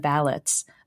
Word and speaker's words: valets: Churchill Downs' 13-member team of valets, the workers who prepare horses and jockeys for each race, and yes, according to Travis, that is how you pronounce it,